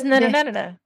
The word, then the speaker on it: Nehemia: I'm also probably gonna butcher their name, but it's Nehemania something? – Princess Nehemia. Nehemia.